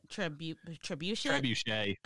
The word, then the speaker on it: trebuchet: Okay, but didn't they make, I don't know how you pronounce it, it's those medieval rock throwing, is it a trebuchet? – Trebuchet. – Okay, trebuchet. Thank you.